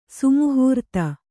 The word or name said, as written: ಸಮಾಹೃತಿ